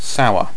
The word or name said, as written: sour